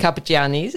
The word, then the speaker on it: Carpigiani: pandemic and you were in, intending, fully intending to go, I'm going to again try and pronounce this correctly, but the Carpigiani's, is that Carpigiani. correct? – Carpigiani's, Gelato University. Yes.